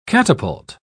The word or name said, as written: catapult